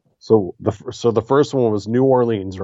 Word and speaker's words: New Orleans: It's like it's one word. New Orleans.